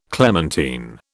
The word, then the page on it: clementine: British English